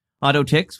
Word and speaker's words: AU10TIX: AutoTix, which is spelled AU10TIX, confirmed the breach happened about 18 months ago, and that the compromised credentials were revoked after what they called a thorough investigation.